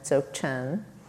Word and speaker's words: Dzogchen: So the practice comes out of a lineage in this Tibetan tradition called Dzogchen, and Dzogchen means, it's spelled like D-Z-O-G, and then Chen, C-H-E-N, Dzogchen.